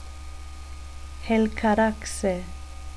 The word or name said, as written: helcaraxe